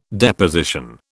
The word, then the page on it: Deposition: British English